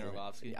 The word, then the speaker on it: Orlovsky: Dan Orlovsky.